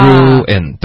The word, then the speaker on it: ruin: 哇，ruin，对，ruin，在词典里面你会看到它发 ruin，对不对？这两个音节，可是很多学生说快的时候呢，发成什么呢？